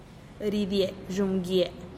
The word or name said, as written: ridie dxumm gie